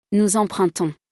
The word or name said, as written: nous empruntons